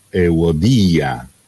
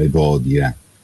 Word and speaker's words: Evodia: Esorto Evodia ed esorto anche Sintiche, qualcuno potrebbe dire, alla linea 12 l'accento è Euodia, perché in italiano Evodia?